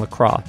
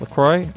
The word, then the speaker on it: LaCroix: We're, of course, open to your ideas and suggestions, even if it's something simple like telling me to stop mispronouncing LaCroix. Apparently, I was doing that a few episodes back. I kept saying LaCroix. It's LaCroix.